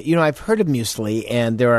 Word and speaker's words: muesli: The Swiss call it M-U-E-S-L-I, muesli.